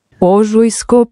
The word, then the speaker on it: Ožujsko: Warte mal, wie spricht man es aus? – Ožujsko pivo. – Ožujsko pivo, so.